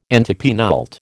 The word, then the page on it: antepenult: American English